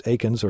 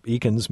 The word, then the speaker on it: Eakins: Our guest this morning is Sidney Kirkpatrick, and he is the author of a new biography of Thomas Aikens, or Eakins, maybe. That's kind of the way that I've always heard it. E-A-K-I-N-S is the way the name is spelled, The Revenge of Thomas Aikens, and it's published by the Yale University Press.